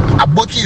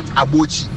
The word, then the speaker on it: Aboki: So Aboki, Aboki is the right pronunciation. Aboki, not Abochi.